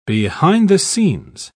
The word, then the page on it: behind the scenes: British English